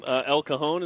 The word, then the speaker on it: El Cajon: And that's where Nick Kurtz is from, El Cajon, is that how you say it? – Yes.